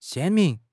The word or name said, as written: 鲜明